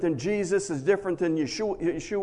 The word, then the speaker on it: Yeshua: In the actual Hebrew, it'd be Yeshua. They don't use a J sound, so it'd be Yeshua. But we always call it Joshua in English.